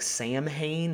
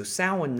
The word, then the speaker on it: Samhain: It looks like Samhain. It's S-A-M-H-A-I-N, but it's pronounced Samhain.